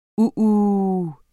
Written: uhu

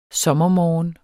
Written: sommermorgen

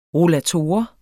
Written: rollator

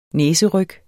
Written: næseryg

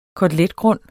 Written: koteletgrund

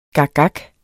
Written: gakgak

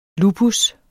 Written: lupus

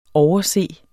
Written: overse

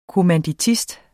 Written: kommanditist

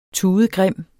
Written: tudegrim

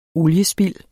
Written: oliespild

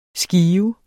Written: skive

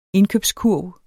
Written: indkøbskurv

